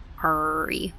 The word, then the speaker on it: Ari: Ari is kind of an R name, or at least it's pronounced an R. – Huh. R-E, yeah. – Ari, like a pirate.